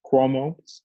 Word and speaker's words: Cuomo: So I do want to mention, like, remember Governor, I guess, Cuomo, that's how you pronounce it? – Cuomo.